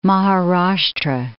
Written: Maharashtra

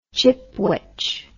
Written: chipwich

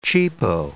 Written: cheapo

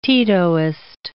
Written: Titoist

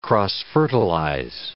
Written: cross-fertilize

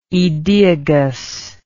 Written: aedeagus

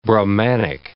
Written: Brahmanic

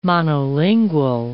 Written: monolingual

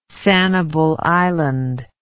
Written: Sanibel Island

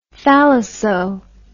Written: thalasso

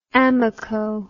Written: Amoco

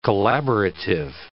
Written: collaborative

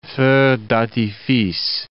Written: feux d'artifice

